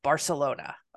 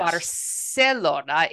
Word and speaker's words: Barcelona: Barcelona in the Latin American pronunciation, which is how I learned Spanish, mm-hmm. Has an S sound, but in Spain it has more of a th sound.